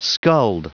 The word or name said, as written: skulled